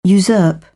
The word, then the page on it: usurp: British English